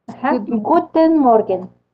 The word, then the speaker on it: guten Morgen: Um, good, good morgen. guten morgen. – guten morgen. – Ah, ah, guten morgen. Ya, richtig. Bien.